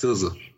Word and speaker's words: SZA: I think her name produ- I uh, pronounced SZA. SZA. Okay, my bad. Just like RZA. That's. Um, well, hmm. yeah, like like like the RZA.